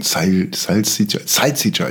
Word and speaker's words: Salsiccia: Salsiccia. Ich glaube, so wie die deutschen Gastronomen alles auf der ersten Silbe betonen, damit sofort jeder weiß, worüber gesprochen wird.